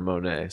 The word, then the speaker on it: Moné: She said Money. It said it's pronounced Money when she says it, but everybody's been calling her Moné, so I don't know.